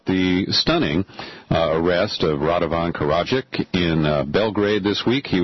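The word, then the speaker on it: Karadžić: So like car in a ditch. Karadzic.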